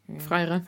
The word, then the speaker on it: Freire: Um I actually can't pronounce his name, but Paulo Fier, Fier, Freire. Freire.